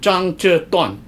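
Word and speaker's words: Zhang Zhe Duan (张择端): Zhang Zhe Duan is his Chinese name.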